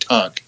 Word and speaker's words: Tank: Yeah, I think they do have a website, I haven't looked at it in a long time, Christian Hospital at Tank, spelled like tank.